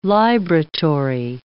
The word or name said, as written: libratory